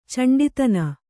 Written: ಚಂಡಿತನ

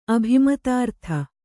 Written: ಅಭಿಮತಾರ್ಥ

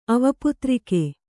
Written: ಅವಪುತ್ರಿಕೆ

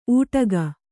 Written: ಊಟಗ

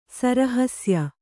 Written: ಸರಹಸ್ಯ

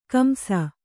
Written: ಕಂಸ

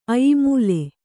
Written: ಅಯ್ಮೂಲೆ

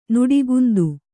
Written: ನುಡಿಗುಂದು

